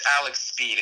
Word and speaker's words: Alex Speid: So it's Alex Speid. It's just spelled differently.